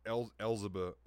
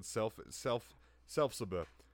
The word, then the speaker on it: Elphaba: I said Selfsaba.